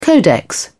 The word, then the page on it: codex: British English